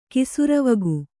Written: ಕಿಸುರವಗು

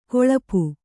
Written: ಗಳಪ

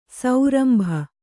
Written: ಸರೋಂಬು

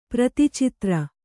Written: ಪ್ರತಿಚಿತ್ರ

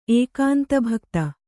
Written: ಏಕಾಂತಭಕ್ತೆ

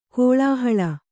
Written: ಕಲ್ಲುಹಾಲೆ

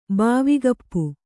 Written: ಬಾವಿಗಪ್ಪು

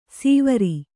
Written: ಸೀವರಿ

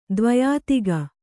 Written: ದ್ವ್ಯಧಿಕ

